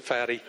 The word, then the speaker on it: Fatih: F-A-T-I-H, but he pronounces it Fatty.